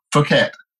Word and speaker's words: Phuket: Uh, Phuket. Phuket.